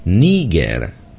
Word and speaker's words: Niger: Když se podíváme zkrátka kamkoliv, tak uvidíme, že nezávisle na tom, zda šlo o dobu Sovětského svazu nebo současného Ruska, je správná výslovnost s přízvukem na první slavice, tedy Niger.